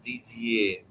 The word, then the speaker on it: Didier: It's a French name. It's supposed to be pronounced Didier, but nobody in my family ever said that.